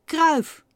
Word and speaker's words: Cruijff: En hoe spreek je het uit? Kruif maar voor internationaal publiek heeft hij de letters in zijn naam een beetje aangepast.